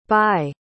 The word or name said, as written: baigh